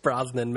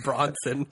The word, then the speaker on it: Brosnan: Pierce Brosnan, man. You always call him Bronson.